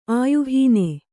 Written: ಆಯುಹೀನ

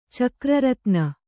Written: ಚಕ್ರರತ್ನ

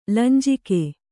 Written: ಲಂಜಿಕೆ

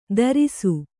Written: ಧಾರಿಸು

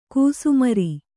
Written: ಘಸ್ಮರ

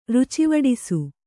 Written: ರುಚಿವಡಿಸು

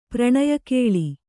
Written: ಪ್ರಣಯಕೇಳಿ

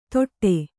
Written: ತೊಟ್ಟೆ

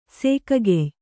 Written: ಸೀಗೆಕಾಯಿ

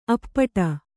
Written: ಅಪ್ಪಟೆ

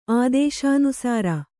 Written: ಆದೇಶಾನುಸಾರ

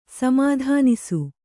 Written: ಸಮಾಧಾನಿಸು

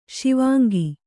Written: ಶಿವಾಂಗಿ

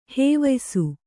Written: ಹವ್ಯಾಸ

